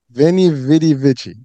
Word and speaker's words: Veni, Vidi, Vici: You know, so Veni, Vidi, Vici.